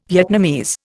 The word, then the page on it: Vietnamese: Indian